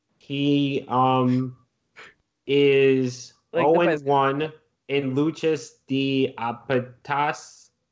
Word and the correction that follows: Lucha de Apuestas: matches. I'm pretty sure I mispronounced that. – Lucha de Apuestas? – Yes.